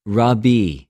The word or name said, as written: ῥαββι